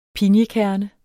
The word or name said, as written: pinjekerne